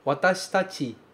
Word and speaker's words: watashitachi: É curiosa a pronúncia do watashitachi, porque esse shi, ele quase some, ele quase vira um sh. Watashitachi, watashitachi, ok? A gente não costuma falar watashitachi, watashitachi. Esse shi quase não aparece como shi, ok?